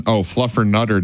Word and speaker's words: Fluffernutter: And happy National Fluttermutter Day. – Excuse you? – Fluffermutter Day. – Nope. Try again. – Not for you? – You're still saying it wrong. – Fluffernutter Day. Fluffernutter Day. Happy Fluffernutter Day.